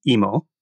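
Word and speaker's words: imo (芋): But sweet potato is pronounced imo.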